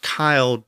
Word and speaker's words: cild: And I noted that it was originally spelled C-I-L-D, but it was pronounced Kyled, but then eventually shifted to child.